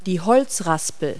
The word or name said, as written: woodrasp